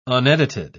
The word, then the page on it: unedited: British English